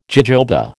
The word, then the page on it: jojoba: American English